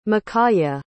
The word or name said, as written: mikaia